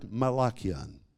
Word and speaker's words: malakian: Then you come to the second category of sickness which Jesus healed, and this is the Greek word malakion. If you're taking notes, you spell it M-A-L-A-K-I-A-N, the word malakion.